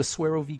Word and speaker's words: Suero: By the way, that's spelled S-U-E-R-O, Swero, V-I-V, V. It's the French pronunciation.